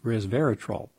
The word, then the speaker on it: resveratrol: And it is the resveratrol, R-E-S, vera, V-E-R-A, trol, T-R-O-L, three syllables, resveratrol.